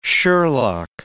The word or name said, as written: sherlock